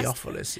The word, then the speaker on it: Theophilus: most excellent Theophilus," how do you say that, Pastor? Theophilus, yeah.